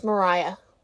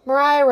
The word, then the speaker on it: Maria: All right, let's get into the sticky situation of their marriage the Maria Reynolds affair. Actually, I think it's pronounced Mariah. I think it's pronounced Mariah. Mariah Reynolds.